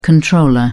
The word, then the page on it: comptroller: British English